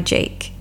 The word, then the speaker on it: JVKE: The channel song for today is Golden Hour by Jake, and it's spelled J-V-K-E.